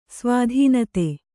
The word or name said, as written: ಸ್ವಾಧೀನತೆ